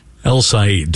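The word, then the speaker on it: El-Sayed: How would you say that? Am I saying it correctly? – El Sayed. Dr. El Sayed.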